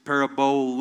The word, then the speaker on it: parabole: The word parable, in fact, it's a Greek word, and it's parabole with a little accent on the end, but it's parable.